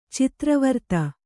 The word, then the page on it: ಚಿತ್ರವರ್ತ: Kannada